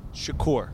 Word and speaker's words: Shakir: Even though it looks like Shakir, he likes Shakur.